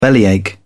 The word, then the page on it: bellyache: British English